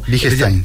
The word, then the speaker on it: Wittgenstein: ¿Me pueden recomendar un libro que trate de filosofía de Wittgenstein? ¿Está bien pronunciado? – Wittgenstein, sí.